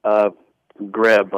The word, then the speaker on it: Grabe: Grabe, like the A's not even there.